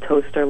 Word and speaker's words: Towcester: And then I'm working on this huge project called Toaster Lodge, which is spelled T-O-W-C-E-S-T-E-R. It's Tau Sister,